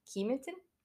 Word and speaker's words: Kematen: posted from the town of Kimaten? Kematen. And yes, I did look up how to say it, but, you know, don't come for me.